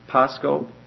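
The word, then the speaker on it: pascho (πάσχω): It's pascho. P-A-S-C-H, long O, pascho.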